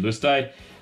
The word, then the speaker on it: Luzteh: And then Luzte, which could be pronounced completely different. This is the new L-U-Z-T-E-H. – L-U-Z-T-E-H. Yep, okay, what you said.